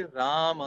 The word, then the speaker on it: राम: तो यदि आपको राम का शुद्ध उच्चारण सीखना है तो मरा मरा मरा मरा बोलिए आप, फिर रुक जाइए रा के पीछे, रुक रहे हैं तो म के पीछे रुक जाइए, राम राम राम राम, ये हो गया। तो यही राम का शुद्ध उच्चारण है।